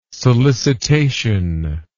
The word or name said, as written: solicitation